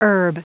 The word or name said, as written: herb